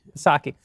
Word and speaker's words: Psaki: Psaki.